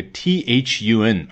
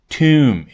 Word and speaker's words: Thun: 唯一要注意的可能就是 Greta Thunberg 啊，这位少女她的名字的读音啊，的确，这个 Greta 可能还比较简单，关键是她的姓 Thunberg 开头那个 T H U N 啊，不是 Thun 或者 Thun，而是 Tomb，也就是 T H，听上去是 T 这个发音啊，其实呢也很好记啊。